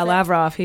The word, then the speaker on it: Lavrov: Yeah, Lavrov.